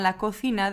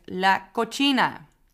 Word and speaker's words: cocina: En wat ik ook vaak voorbij hoor komen is in plaats van cocina, la cocina, de keuken, dat er wordt gezegd la cocina. Alsof de C op zijn Italiaans wordt uitgesproken.